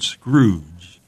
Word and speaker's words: Scrooge: Most people don't probably know that when Dickens first wrote that tale, the word Scrooge was to be pronounced Scroog. – Scroog. – Did you know that? – Scroog? No, Scroog. – Yeah. Scroog. S-C-R-O-O-G-E was to be pronounced Scroog.